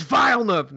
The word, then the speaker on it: Villeneuve: And I'd be like, you know, Denis Villeneuve.